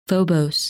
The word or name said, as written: Phobos